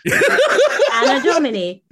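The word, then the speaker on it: Anno Domini: Anadomini. There we go. – The year of our Lord. – Anadomini. Think of anodome. It's a dome.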